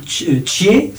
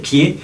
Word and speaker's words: Chie: Nous avons vu Chie, ça se prononce je crois comme ça, la petite peste, et en français Kie, ça s'écrit C-H-I-E, alors naturellement on prononce Kie, sinon ce serait gênant.